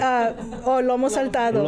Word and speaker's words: Lomo saltado: Salamataro? How do you say it? – Lomo saltado. – Lomo saltado.